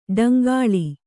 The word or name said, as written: ಡಂಗಾಳಿ